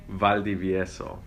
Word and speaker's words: Valdiviezo: Valdiviezo.